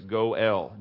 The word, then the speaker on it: goel: it's pronounced like this, Goel, G-O-E-L.